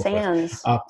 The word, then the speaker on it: Sans: Is it Sans or Sans? I hear it pronounced both. – Sans. It's Sans.